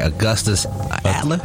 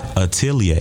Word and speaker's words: Atelier: Augustus Adler? – Atelier. – It's a little tricky. It's a French word. It's French for workshop.